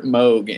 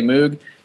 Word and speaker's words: Moog: It's Robert Moog, and even though it looks like phonetically you would say Moog, it is Moog. That's why there's a Moog Rogue. There's a model of Moog called the Rogue, because it rhymes with Moog.